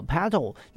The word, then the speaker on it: petal: 白色的花瓣，我们来看到这个单字哈，petals，好，petal。那你在念的时候呢，不要把它念成特，好，可以念成 petal。好、哦，它就那个因为是无声子音，但是呢，因为它后面遇到母音的关系哈、哦，所以它的发音哈、哦，它的发音呢就是 petals，好、哦，就是有 do 的音哦。